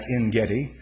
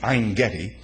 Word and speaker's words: Ein-Gedi: As a matter of fact, at En-Gedi, Ein-Gedi, Ein in Hebrew is a spring. That's what the word means. A correct pronunciation will be Ein-Gedi.